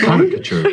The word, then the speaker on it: caricature: Um, apparently, the way that I say caricature is all wrong. – Yeah, that's it's caricature. yeah,